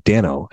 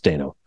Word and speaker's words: Dano: And Paul Dano, and yes, I keep emphasizing Dano, because everyone always thinks it's pronounced Dano. And then I made sure I read an article in the Hollywood Reporter, which it said, rhymes with Drano. So it is actually pronounced Dano.